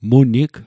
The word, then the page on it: Munig: Mi'gmaq